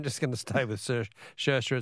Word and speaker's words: Saoirse: Helen from Footscray says, Phil, the Irish name Saoirse is pronounced either Saoirse or Saoirse, depending on which county you come from.